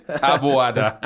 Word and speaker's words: Taboada: Ah oui, je le prononce bien? Taboada. – Oui, oui, oui.